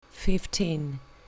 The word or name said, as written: fifteen